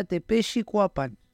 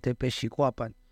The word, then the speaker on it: Tepexcuapan: Por ahí me han dicho que la forma correcta de pronunciarlo sería Tepexcuapan. – Ok. – Así como en suavecito, como Tepexcuapan.